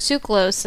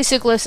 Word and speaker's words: Tsoukalos: He's like, George Tsoukalos, and I'm like, okay, hopefully that's the right way. I didn't hear George say, no, it's pronounced... or something, you know. So hopefully Tsoukalos is right.